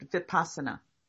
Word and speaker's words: Vipassana: But Vipassana. It's pronounced Vipassana.